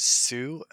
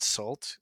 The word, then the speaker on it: Sault: It's called Untitled Black Is, and the artist is Sault, S-A-U-L-T. I just realized I had been pronouncing it salt, and then today at work I was doing that too. – Corrected, and I felt like such a doy doy because it's spelled just like Sault Ste. Marie, so it'd be like calling it Salt Ste. Marie.